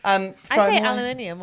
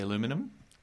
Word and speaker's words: aluminium: Aluminium. What do you say? – Aluminium, yeah. – Aluminium. – Americans say aluminum. – We say aluminium.